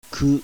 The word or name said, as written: KU